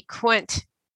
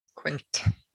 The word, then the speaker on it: queynte: The naughty bit is that they call it Q-U-E-Y-N-T-E, Quint. – Quint. – Yep. Or pronounced other ways.